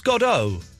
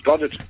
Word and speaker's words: Godot: I thought it was pronounced Godot. – Well, I've always pronounced it Godot.